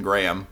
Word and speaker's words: Graham: And me as always as my birth name is Kyle Duncan Graham. I'm here. – It sounded like you put an extra couple letters in your last name. – Gray. No, that's the way it's supposed to be pronounced.